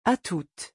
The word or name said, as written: atoute